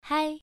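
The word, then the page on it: hai: tone 1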